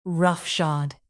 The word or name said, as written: roughshod